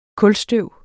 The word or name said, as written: kulstøv